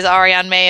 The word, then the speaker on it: Ariane May: Now, I apologize, Ariane May, and anyone's listening, that I'm from Texas and she's from Australia.